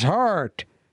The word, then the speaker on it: Haartte: The name's Hart. Detective Hart. Two A's, two T's. Spell it right. Say it right. – Heat. – Hart. – Heat. – Hart. – Hoyt.